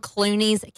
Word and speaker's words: Kluenies: They're from Clooney's Camo. But it's said just like George Clooney, but it's spelled with a K. So K-L-U-E-N-I-E-S, Clooney's Camo.